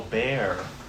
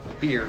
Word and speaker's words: bier: B-I-E-R? – Yeah. – Oh, bear. – Not like the paint. – Beer.